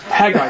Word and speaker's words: Haggai: Am I pronouncing it right? – Haggai, thank you.